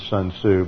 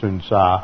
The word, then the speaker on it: Sun Tzu: You may mispronounce it as Sun Tzu, but it's Sun Tzu.